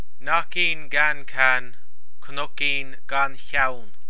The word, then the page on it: Cnoicín gan Cheann: Irish